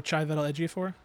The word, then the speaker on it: Chiwetel Ejiofor: One of the things is that they said that the actor, and I'm going to butcher his name. You say it better than me. Chiwetel. – Oh, Chiwetel Ejiofor? – There it is. I messed it up.